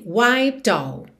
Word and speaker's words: wiped: E já na palavra wiped, o E não é pronunciado. Então você tem wiped all, do P já vai pro D, que já junta no O.